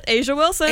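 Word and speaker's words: A'ja Wilson: Since we spoke about women's sports just a hot minute ago, Asia Wilson, is that how you say it? – Yeah, Asia Wilson.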